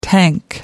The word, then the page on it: tank: American English